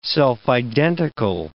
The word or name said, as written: self-identical